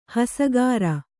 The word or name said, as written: ಹಸಗಾರ